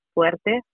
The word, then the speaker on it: Fuerte: my last name, Fuerte, F as in Frank, U, E, R, T like tomato, E.